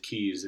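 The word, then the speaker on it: cays: So it's, in the Bahamas, they spell it C-A-Y. – Yeah, but they pronounce it keys, and a key is a small island.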